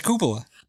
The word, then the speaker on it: cupola: A cupola. – A cupola. – Yeah. – Is that how it's said? – Yeah, it's a cupola.